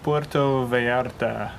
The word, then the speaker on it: Puerto Vallarta: How do you pronounce it? – Puerto Vallarta. – Puerto Vallarta. – Puerto is like a port. – Yeah. – Vallarta. I think so. Puerto Vallarta, people say.